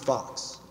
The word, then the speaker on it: Fuchs: It's actually pronounced Fox, F-O-X, but it's spelled F-U-C-H-S.